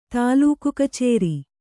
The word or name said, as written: ತಾಲೂಕುಕಚೇರಿ